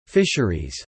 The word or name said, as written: fisheries